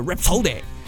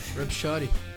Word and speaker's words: Rhapsody: É isso aí a pronúncia, Rhapsody. Isso aí. Rhapsody.